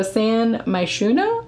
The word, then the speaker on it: San Myshuno: S-A-N space M-Y-S-H-U-N-O. So San Myshuno, I assume is how you say it.